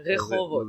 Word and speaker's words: Rehovot: I'm going to learn how to pronounce it properly, too. – Yeah, Rehovot. – Rehovot. – Yeah, good. You're getting better.